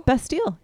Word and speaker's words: Bastille: Bastille.